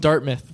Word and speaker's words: Dartmouth: No, it's pronounced Dartmouth.